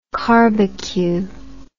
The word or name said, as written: carbecue